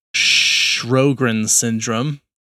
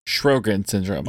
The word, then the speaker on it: Sjögren's syndrome: And this is pronounced, I looked this up to the best of my ability, it's Sjögren's syndrome. – Sjögren's syndrome.